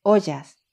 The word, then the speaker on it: Ojas: Ollas, que se escribe O-J-A-S.